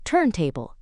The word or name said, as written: turntable